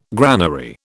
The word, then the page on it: granary: British English